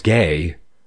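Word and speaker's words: gay (γῆ): The word earth in Strong's is gay, G-H-A-Y, that's the pronunciation,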